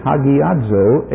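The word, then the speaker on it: hagiazo (ἁγιάζω): This Hebrew word is matched by a Greek word in the New Testament, which is hagiazo, H-A-G-I-A-Z-O, and both of these words mean set apart.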